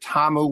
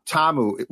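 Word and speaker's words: Ta'amu: I heard Tiamu. I heard Tamu, which I think is correct. – Tamu, which I believe is the correct one.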